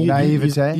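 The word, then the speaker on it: naïveté: How do you say that word? – Yeah, I mean, naivete. – You said it right.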